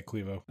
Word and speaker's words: Clevo: So sure, they're using what's Clevo. Am I saying that right? – Yeah, Clevo.